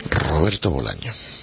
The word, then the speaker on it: Bolaño: Roberto Bolaño. No sé por qué, ahora lo he dicho bien, pero siempre, bueno, no siempre, no hay ni siempre ni cuándo, pero sale la muletilla, está en siempre. Se me cuela la S, no sé por qué.